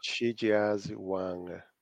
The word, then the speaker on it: Shijiazhuang: Shijiazhuang. I don't know how you pronounce that. – No, but Shijiazhuang.